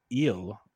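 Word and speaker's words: Gil: Luis Eel, the G is silent, it sounded like on the broadcast.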